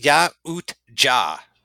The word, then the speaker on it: Yautja: It's called Yautja. Yautja. It's pronounced Yautja.